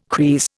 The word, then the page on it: kris: Indian